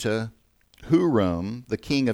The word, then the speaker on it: Huram: And it can be spelled and pronounced Huram, H-U-R-A-M, which is how it reads in 2 Chronicles.